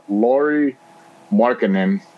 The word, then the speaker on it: Markkanen: So I'm pretty much looking at all the Lauri Markkanen. I think that's how you say his name. – Yep.